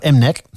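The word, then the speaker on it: MNEK: M-N-I-G, spreek je tijd, maar er staat M-Neck.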